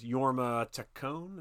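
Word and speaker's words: Jorma Taccone: He still lives at home with his stepfather, played by Ian McShane, his stepbrother, which is Yorma Taccone.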